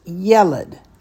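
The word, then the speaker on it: yeled (ילד): The first one gets the accent. So this word is pronounced yeled. It's not yeled, it's yeled. Hear the difference? The accent is on the ye. Yeled, not yeled.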